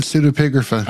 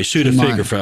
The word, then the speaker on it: pseudepigrapha: Not in the Not in the pseudepigrapha. – I'm sorry, pseudepigrapha. I said it wrong.